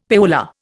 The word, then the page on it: payola: Indian